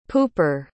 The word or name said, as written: pooper